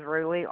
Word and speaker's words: Roelie: My first name is Roelie, R-O-E-L-I-E.